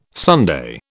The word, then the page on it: sundae: British English